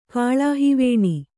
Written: ಕಾಳಾಹಿವೇಣಿ

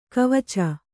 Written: ಗೆವಚು

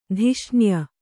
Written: ದೂಷಣೀಯ

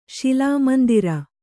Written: ಶಿಲಾಮಂದಿರ

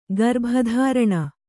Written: ಗರ್ಭಧಾರಣೆ

